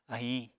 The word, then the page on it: ahiñ: Apatani